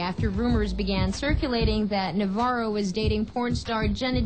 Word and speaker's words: Navarro: So how does he say his name? – Navarro? Yeah.